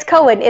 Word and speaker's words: Cooan: It's Cohen. It's the Cohen. Original Jewish pronunciation.